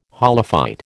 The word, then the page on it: holophyte: American English